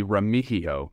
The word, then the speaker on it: Remigio: Yeah, it's actually Remigio. The G is a very soft, silent G. There you go, Remigio.